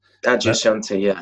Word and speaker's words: Adyashanti: Adyashanti, is that right? – Adyashanti, an American spiritual teacher.